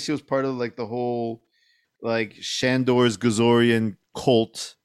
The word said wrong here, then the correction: Gozerian: Did you just mispronounce Gozer? – No, Gazorian. – No, Gazorian is their Gazorian cult. – Is the cult. – Gozerians. – Gozerians. Gozerians. There you go. – Fuck. – It's the Chicago in me, man. – Amateurs. – Oh, my goodness. Okay. Anyway, Gozerian, am I pronouncing it right there, mister? – Yes.